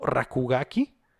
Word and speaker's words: RKGK: Y el otro juego de un estudio mexicano se llama RKGK, pronunciado Rakugaki, que tengo entendido Rakugaki.